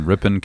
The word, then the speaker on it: Ripon: We are recording this podcast in Pastor Zach's office here in Ripon, California at Almond Valley Christian Reformed Church.